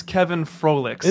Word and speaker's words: Kevin Froleiks: And I believe it's Kevin Froleiks.